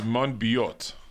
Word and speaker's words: Monbiot: And he's British, so it's Monbiot.